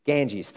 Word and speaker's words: Ganges: Ganges, thank you.